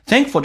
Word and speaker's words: thankful: 比如说那个梅花音 a 发成 A 这个发音，在美式发音，在美式发音当中，当 A 碰到一个长鼻音， A 会变成 A，所以变成 thing，而且那个 k 是不发音的。在美式发音，两个辅音在一起，只用发后面一个，变成 thankful。 F-U-L 通常那个音标是 F 加个 A 再加个 L，但 A 跟 L 在一起，一般 A 会被 L 吸收，这是一个规则，就变成直接发成 F-L，叫 full。